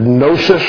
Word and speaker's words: gnosis: They were to pursue various steps toward philosophy, based on the Greek word sophia, and wisdom, and knowledge, the Greek word gnosis, with a silent G on the front.